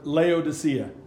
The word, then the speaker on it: Laodicea: Is that how you pronounce it? – Laodicea. – Laodicea, sorry.